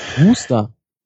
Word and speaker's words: Worcester: Wie Worcester Soße ausgesprochen wird? – Worcester Soße? – Wooster. – Wooster, ja, das habe ich auch schon mal gehört, ja.